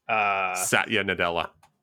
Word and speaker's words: Satya Nadella: Satya Nadella.